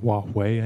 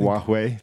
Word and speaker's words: Huawei: So, you know, if you've read this ongoing saga with Huawei, if that's how it's pronounced. – Huawei, I think. – Huawei, thank you.